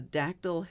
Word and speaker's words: Dactyl: It's called the Dactyl Hill Squad. – Spell that for me. Uh, D A C T Y L. – Dactyl. – Dactyl, as in pterodactyl.